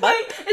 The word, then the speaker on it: Butte: Is it B-U-T-T-E? – Yeah. – Because I feel like that might be... – Let's see. – I feel like that's Butte. – How to pronounce... – Because now I'm curious. – Butte. – Butte. – Elephant Butte, New Mexico.